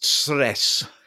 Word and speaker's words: Cres: Well, it's spelt Cres, but it's pronounced Sres.